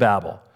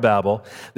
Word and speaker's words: Babel: In fact, let me talk to you about the Tower of Babel. Some of you say Tower of Babel. I know you can pronounce it either way. I say Tower of Babel.